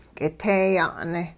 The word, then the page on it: k'iteeya'ne': hupa